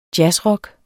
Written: jazzrock

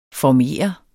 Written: former

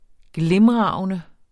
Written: glimragende

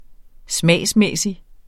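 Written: smagsmæssig

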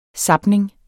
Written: zapning

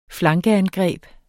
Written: flankeangreb

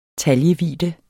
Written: taljevidde

